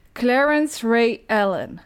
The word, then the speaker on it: Clarence Ray Allen: Clarence Ray Allen. – Dat heb je best wel goed uitgesproken.